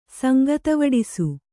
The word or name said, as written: ಸಂಗತವಡಿಸು